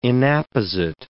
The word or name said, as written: inapposite